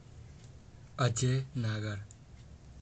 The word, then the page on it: Ajey Nagar: əˈdʒeː ˈnaːɡər